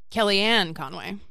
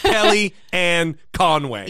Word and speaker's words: Kellyanne Conway: Fuck straight off Kelly Conway. – Kellyanne Conway. – Kelly Ann Conway. – Yes. – Another syllable.